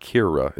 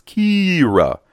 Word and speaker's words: Kira: Well, in the Entertainment Weekly article, when they talk about the different characters, they mention that Kira, that's how you say it, by the way. It's not Kira. It's Kira.